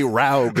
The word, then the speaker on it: Raug: Oh, it's actually Raug like Smaug.